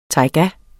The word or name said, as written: tajga